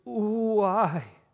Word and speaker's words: ouai: That word, woe it's a word that is meant to be pronounced like the sound of grief that comes out of a grieving widow's mouth. The Greek word is ouai.